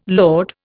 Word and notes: load: Indian

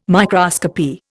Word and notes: microscopy: Indian

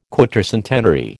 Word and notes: quatercentenary: American English

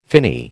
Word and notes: finny: American English

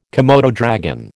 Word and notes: komodo dragon: American English

